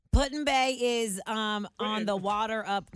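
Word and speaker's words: Put-in-Bay: P-U-T. – Vladimir. That's all I know. – P-U-T space I-N. – Oh, Puddin Bay.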